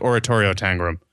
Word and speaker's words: Oratorio Tangram: No, it's like Oratorio Tangram.